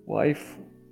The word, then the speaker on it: waifu: É porque esse fu aí é de wife, só que é na pronúncia de, é de japonês, cara. – Hum. – Waifu.